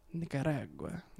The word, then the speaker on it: Nicaragua: So, in 1854, a civil war broke out in Nicaragua.